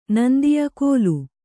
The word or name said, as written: ಗೋಲು